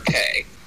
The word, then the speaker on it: Karpeh: Cape. Cape. – Cape. – K-A-R-P-E-H.